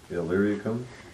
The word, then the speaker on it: Illyricum: I'll try to pronounce this right, maybe you can help me, Brother John, Illyricum, it's Illyricum.